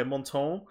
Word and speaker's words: Montand: The D is silent. – Okay, Montand as very Mario.